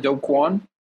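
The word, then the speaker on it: Do Kwon: So, okay, just in case somehow people don't know, so Doquan, am I pronouncing this right? Doquan? – Yeah.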